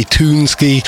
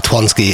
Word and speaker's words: Twonsky: And Martin thought it might be Toonsky. I thought Twonsky sounded better. And during my research last night, I came across this on SoundCloud, and he'd actually replied and said Twonsky is the way to pronounce his name, just in case you were wondering.